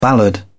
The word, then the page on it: ballad: British English